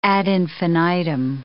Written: ad infinitum

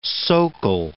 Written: socle

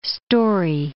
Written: storey

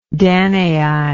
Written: danai

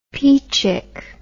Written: peachick

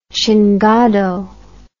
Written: chingado

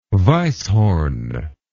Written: weisshorn

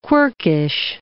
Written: quirkish

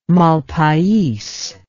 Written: malpais

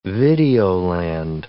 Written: videoland

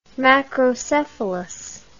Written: macrocephalus

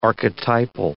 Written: archetypal

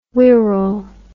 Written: Wirral

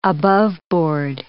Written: aboveboard

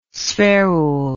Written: spherule

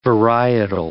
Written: varietal

